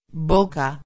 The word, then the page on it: boca: espanhol